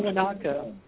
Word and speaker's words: Tiwanaku: Tiwanaku. – Tiwanaku actually did survive the flood.